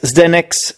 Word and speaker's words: Zdenek: Just try to write Zdenek's English Podcast. My name is Zdenek. It's spelled Z-D-E-N-E-K, apostrophe S, which is the genitive case, English Podcast.